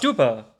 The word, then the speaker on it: stupa: It's a soft T sound. – Oh, is it? – Not it's Stupa.